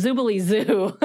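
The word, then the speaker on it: Zoobilee Zoo: Zoobly-Doo. Do you remember that? – Zoobly-Doo? No. – Zoobly-Doo? – Zoboomafoo? – No. – That's a fun name. – Oh, no. That's not how you say it. It's Zoobly-Zoo.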